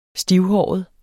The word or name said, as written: stivhåret